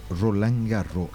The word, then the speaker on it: Roland Garros: Que para muchos la pronunciación a nivel es Roland Garros. La S por lo general se obvia. No se elimina, pero se obvia. En el escrito está, pero en la pronunciación por lo general ocurre que es se obvia la S.